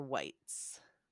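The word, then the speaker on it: Wights: Is it Waits or Whites? – Whites.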